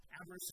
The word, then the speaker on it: Avaris: Avaris is the English pronunciation,